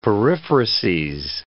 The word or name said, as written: periphrases